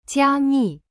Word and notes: 加密: jia mi

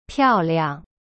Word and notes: 漂亮: piao liang

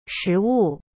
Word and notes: 食物: shi wu

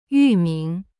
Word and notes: 域名: yu-ming

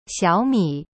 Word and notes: 小米: xiao mi

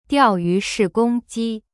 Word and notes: 钓鱼式攻击: diao yu shi gong ji

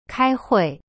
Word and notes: 开会: kai hui

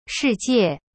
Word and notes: 世界: shi jie

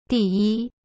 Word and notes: 第一: di-yi

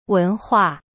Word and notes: 文化: wen hua

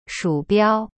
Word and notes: 鼠标: shu biao

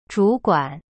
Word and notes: 主管: zhu-guan

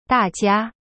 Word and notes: 大家: da jia

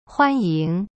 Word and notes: 欢迎: huan-ying

